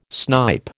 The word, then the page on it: snipe: American English